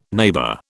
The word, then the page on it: neighbour: British English